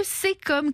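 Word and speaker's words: caecum: Et j'avoue que j'aurais prononcé Kekom moi-même. Mais c'est bien le Sécom qu'il faut dire.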